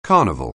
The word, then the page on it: carnival: anglais